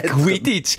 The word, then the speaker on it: Quidditch: Quitterschle. – Quitsch, Indien, so. Quitsch. Quidditch.